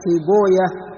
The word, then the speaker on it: سيبويه: نعم، قال: وعبد الله بن أحمد بن شبوية، وهو الخزاعي، وهو الخزاعي، وهذه الأسماء التي في نهايتها مثل نفطويه وسيبويه وراهوية، فهذه الأسماء أهل الحديث ينطقونها شبوية، نفطوية، سيبوية، لأنه جاء عن إبراهيم النخعي بأن ويه اسم شيطان، أن ويه اسم شيطان، هكذا ذكر عن، نقل عن إبراهيم النخعي، نقل ابن حجر، فأهل الحديث ينطقون هذه الأسماء شبوية راهوية نعم نفطوية، ولا يقولون نفطويه أو سيبويه نعم.